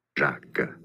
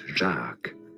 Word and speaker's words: Jacques: Jacques. It is French for Jack. You could say it as Jacques. Jacques. Easy. Jacques. – I don't know what that is, but it was on Google. – It's Jacques.